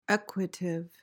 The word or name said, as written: equative